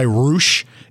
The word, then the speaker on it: Rush: Now, Dragon Lee's brother is, he goes by Roosh. It's spelled like Rush, R-U-S, R-U-S-H, but he goes by Roosh.